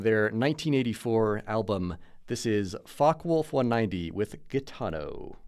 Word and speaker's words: Focke-Wulf: This group is called Focke-Wulf 190, and that's Focke, being very nervous pronouncing that name.